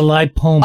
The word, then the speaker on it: lipoma: Well, the other thing I've heard you talk about is lipoma. Is that how you pronounce it? – No, it's a lipoma.